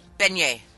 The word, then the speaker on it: beignet: Beignet. – Beignet.